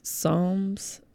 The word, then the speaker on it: Psalms: Psalms. It's a weird word. It's silent P.